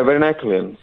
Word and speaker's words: varenicline: I don't know how you say varenicline. How do you say it? – Yeah, varenicline.